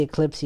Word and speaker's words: Xclipse: As for the GPU, which is one of the unique selling points of this processor, you have the X Eclipse. It's Xclipse, but actually Eclipse, you can pronounce it this way.